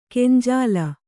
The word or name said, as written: ಗಂಜಾಲ